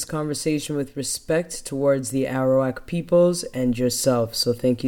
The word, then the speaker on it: Arawak: So I'd like to start by giving your people a land acknowledgement, which are the Arawak.